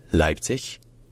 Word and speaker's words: Leipzig: Avec dans les deux cas, bien sûr, l'accent tonique qui est sur la première syllabe. Leipzig, bien Leipzig. Mais jamais avec un ge à la fin, parce qu'en allemand, le ge final se devient toujours un que.